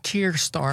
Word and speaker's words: Keir Starmer: Keir Starmer.